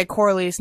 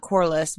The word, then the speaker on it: Corlys: You know, and it's funny because there's some pronunciations where I'm like, I'll always say Corlys, not Corliss, because I feel like I don't want to say anus, so I'll say anus, you know, whatever.